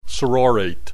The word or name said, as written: sororate